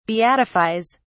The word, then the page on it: beatifies: British English